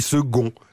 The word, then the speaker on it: second: Il ou elle, je ne sais pas trop, me demande pourquoi le mot second s'écrit-il avec un C alors que nous le prononçons comme un G. – C'est vrai, on dit second.